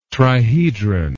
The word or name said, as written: trihedron